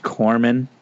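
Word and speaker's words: corpsman: Reilly, otherwise known as the greatest living American actor, plays Ronan Day, a corpseman, in what group in Guardians of the Galaxy? – I know. – It's pronounced Corman.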